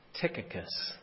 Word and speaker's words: Tychicus: That's one acceptable way to pronounce it in English, Tychicus. That's the first hurdle we have to overcome when we read this passage. Tychicus in Greek, he has a U sound there, but we just read it as it's written or transliterated for us into English, Tychicus.